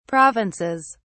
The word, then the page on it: provinces: British English